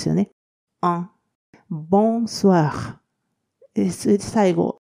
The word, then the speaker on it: Bonsoir: えー、ボンスワー、えー、この、お、お、いとの部分を、おわと発音するので、ボンスワー、えー、そして、えー、がその後つきますので、ボンスワー。